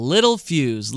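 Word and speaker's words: Littelfuse: Little Fuse. Little, not even spelled like small, but L-I-T-T-E-L, maybe a German name.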